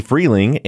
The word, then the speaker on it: Freleng: And for all of you listeners, this is Maggie Freleng and Julieta Martinelli.